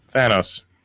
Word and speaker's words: Thanos: Wait, how do they say it? – Thanos. – That's what I said. I thought you were saying Thanos.